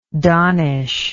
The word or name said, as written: donnism